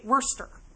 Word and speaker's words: Worcester: Worcestershire, but they say Worcester.